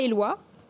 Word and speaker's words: Éloi: Alors, Maison Thierry, c'est une entreprise française créée en 1902 dans les Vosges, à Éloi, je le dis bien. Très bien, c'est exactement comme ça.